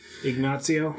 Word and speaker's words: Ignacio: Michael Peña as an uh, I cannot pronounce that Ignacio, I think. – Ignacio. – Yeah, and that that sounds right.